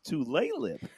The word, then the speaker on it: Tulalip: Tulalip. Does that sound right? I said it wrong. I knew I would. – Yeah, I'm part Native American and I'm from Seattle. It's Tulalip.